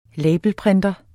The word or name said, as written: labelprinter